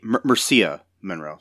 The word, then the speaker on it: Mircea Monroe: And then they do a pity hire of a sexy girl named Mandy, played by Marika Monroe. Mircea Monroe, sorry. I'm bad at pronouncing names.